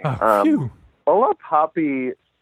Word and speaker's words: Hola Papi: No, you know, it's a little bit of a gringo accent, but otherwise you're right on the money. Hola, Papi, it's an interesting column.